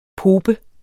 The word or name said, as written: pope